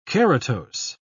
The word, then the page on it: keratose: British English